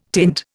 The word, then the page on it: tint: Indian